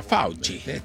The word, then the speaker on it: Fauci: Y para los italianos, Fauci. El doctor. Así se pronuncia Fauci.